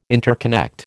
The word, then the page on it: interconnect: American English